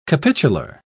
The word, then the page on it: capitular: British English